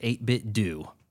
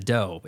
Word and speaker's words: 8BitDo: Too many idiots on YouTube pronounce it 8BitDo instead of dough. It's supposed to be dough like Nintendo.